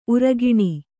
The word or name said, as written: ಊರಗುಣಿ